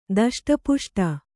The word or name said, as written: ದಷ್ಟಪುಷ್ಟ